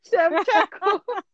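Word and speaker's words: Shevchenko: So, yeah, Chenchenko. Oh, Chenchenko is how you pronounce it. – Like, Chev, like I'm going to Chev your ass with a knife. Chevchenko.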